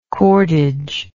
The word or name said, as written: cordage